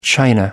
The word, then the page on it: china: American English